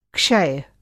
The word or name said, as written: क्षय